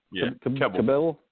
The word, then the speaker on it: Kebbell: Is it Kebbell? – Kebbell.